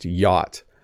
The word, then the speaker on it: yacht: You can't sound this word out, but it's just yacht. You can ignore the C and the H.